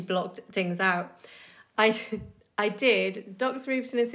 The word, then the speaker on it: Doxorubicin: Doxorubicin.